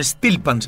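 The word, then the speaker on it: steelpan: Y tiene un instrumento ahí que se llama el Stekpan, creo que se pronuncia así, que es un instrumento tradicional de Trinidad y Tobago. Y sí, tiene su estilo afronáutico. – Un Stilpan se llama.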